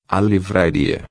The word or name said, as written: livraria